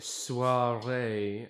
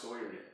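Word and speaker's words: soirée: I was at a soiree earlier on in the week. – That's pronounced soiree.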